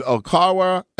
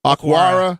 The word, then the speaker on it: Okwara: And then you get a linebacker in Okawara. I can't pronounce his name. Okwara.